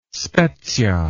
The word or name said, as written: Spezia